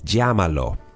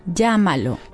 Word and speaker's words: llámalo: How do you pronounce those double L's? Those double L's. So can you pronounce this word for us? Call him? – Llámalo.